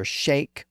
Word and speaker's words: sheikh: The word spelled S-H-E-I-K, or S-H-E-I-K-H, was borrowed from Arabic in the 1500s, and it can be pronounced as either sheikh or sheikh in modern English. And we also have the word spelled G-E-I-S-H-A from Japanese, and at least in American English, it can be pronounced as either geisha or geisha.